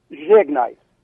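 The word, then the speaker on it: žegnaj: Zhegnai. – Zhegnai? – Zhegnai, and it's spelled Z-E-G N-A-J. But the Z's got a... – Zhegnai. So Zhegnai, and is it America, Ameriku, Ameriki? Huh? – Ameriko. A-M-E-R-I-K-O.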